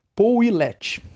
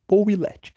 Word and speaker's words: Pouillet: Vai ser obtida através da famosa lei de Poulet, ou não sei falar o nome desse cara em francês não, Pouillet, que se lê, Pouillet, que se escreve no caso.